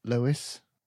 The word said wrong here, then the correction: Louis: Louis? – But I've been saying Lewis the whole thing. Been saying his name wrong for the whole of this half of the episode, but here's Louis from Rialto.